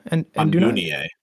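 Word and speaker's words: Andúnië: Andunie. – Andunie. – Andunie. You don't have to roll your R's quite as much. – Yeah. There's no R's in Andunie.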